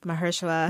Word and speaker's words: Mahershala: Mahershala? I can never say his name. – Mahershala.